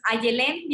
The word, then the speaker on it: Ayelén: Entonces, y la palabra Ayelén tal cual viene, este, porque mucha gente dice, ¿cómo se pronuncia? Entonces, Ayelén bienestar.